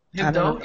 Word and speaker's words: Hebdo: Hebdo, yeah.